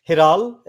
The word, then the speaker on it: Hiral: Yeah, my name is pronounced Hiral.